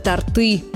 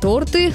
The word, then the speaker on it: торты: Очень часто мы произносим его неправильно – «торты». Почему? Ударение в русском языке подвижное и нефиксированное. При изменении формы слова оно может переходить с одного слога на другой, а может и не переходить. Вот, например, ряд односложных существительных мужского рода – зонт, кот, мост. Во множественном числе это будут зонты, коты, мосты. А вот в слове «торт», как его не изменяй, по числам и падежам ударение всегда остается на первом слоге.